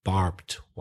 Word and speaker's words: barbed: Sorry, Mamin, but just to correct you now, it's pronounced barbed. It's one syllable and you pronounce the E, but you shouldn't, because ED, usually in the majority of cases, the E is silent, barbed wire.